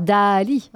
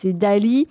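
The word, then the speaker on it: Daaaaaalí!: Le nouveau Quentin Alors Dali, hein, voilà, si je prononce bien, il y a 6A.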